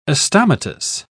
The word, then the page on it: astomatous: British English